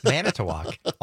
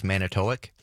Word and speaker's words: Manitowoc: It's Manitowoc. Almost everybody got it right. Although I will say one of the mispronunciations was Manitowoc.